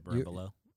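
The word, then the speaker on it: berimbolo: I think like 2 months later, I saw it was called the Baron Bolo. I probably don't even pronounce it right. – You pronounce it Barimbolo.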